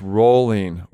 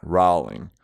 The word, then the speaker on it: Rowling: It's Rowling. Rhymes with bowling. It is not Rowling.